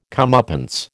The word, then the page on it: comeuppance: American English